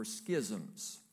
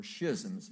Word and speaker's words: schisms: In the Greek, this is the word for schisms, or schisms, depending on how you like to pronounce that word. S-C-H-I-S-M.